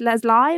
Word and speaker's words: Leslye: It's spelled Leslye. I'm going to say it's Leslie. Leslie. – I bet it's Leslie.